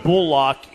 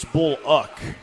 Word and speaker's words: Bullock: And then 7308, it's not Bullock, it's Bullock. Okay, I just pulled up the USC pronunciation guide from last year. BUL-LOCK in all capital letters.